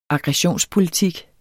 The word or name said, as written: aggression